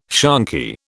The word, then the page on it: shonky: British English